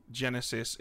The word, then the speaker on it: Genesys: Yeah, and for those of you listening, it's spelled G-E-N-E-S-Y-S. So it's not spelled like normal Genesis. It's spelled cool. – Yeah, and they're about to release a book for it that lets you play Genesis in the Twilight Imperium setting.